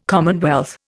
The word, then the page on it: commonwealth: Indian